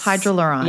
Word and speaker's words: Hydraluron: I'm going to try and say it correctly. – Yeah. – Hydraluron? – Yes. – Hydraluron. – Yes, exactly.